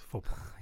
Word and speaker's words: faux pas: Say faux pas. It's faux pas. – Yeah, that you've pronounced correctly, I think.